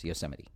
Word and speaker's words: Yosemite: So that's how it's pronounced, Yosemite.